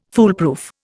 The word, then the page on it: foolproof: Indian